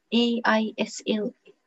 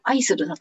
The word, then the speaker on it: aisle: うん、うん、で、その時、店員さんが、あの、あ、 oh, that is on aisle 10 とか言って、で、その、これが AISLE が aisle って発音するって分かってなかったから、うん、アイスルだと思ってるので、